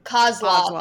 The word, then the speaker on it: Kozlov: It's K-O-Z-L-O-V. – Kozlov, I think.